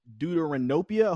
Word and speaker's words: deuteranopia: we saw that you were born with deuteranopia. I hope I'm saying that correctly. – Yeah, you are.